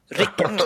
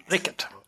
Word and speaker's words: Rickert: Rickert. okay. Rickert. Betoning på första Som stavelsen. Rickert.